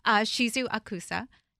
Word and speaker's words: Shizu Okusa: First, we have return guest Shizu Okusa.